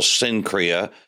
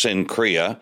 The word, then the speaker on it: Cenchrea: In the Mediterranean, there is an isthmus that begins whichever side you're on, whether it's Cenchrea, it's called Cenchrea, spelled with a C and usually pronounced that way in English, Cenchrea.